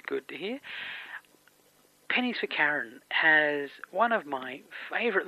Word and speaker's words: Charon: I tend to go with Charon. – Charon.